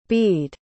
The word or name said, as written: bede